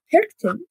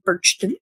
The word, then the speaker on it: Perchten: Perchten, spelled P-E-R-C-H-T-E-N, sometimes pronounced Birchten with a B,